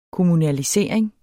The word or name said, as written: kommunalisering